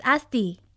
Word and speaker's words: Asti: My name is Asti. A-S-T-I.